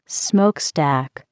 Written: smokestack

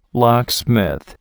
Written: locksmith

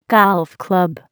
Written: golf club

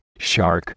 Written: shark

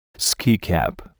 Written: ski cap

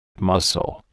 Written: mussel

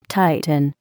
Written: tighten

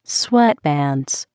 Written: sweatpants

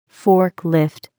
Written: forklift

